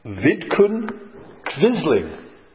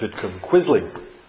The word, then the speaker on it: Vidkun Quisling: There was a man in Norway by the name of Vidkun Quisling. That's how it's pronounced in Norwegian. We would pronounce it Vidkun Quisling.